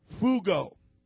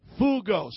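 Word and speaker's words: pheugo: And the way it looks like it's pronounced is fugo. Fugo. So everybody say fugo.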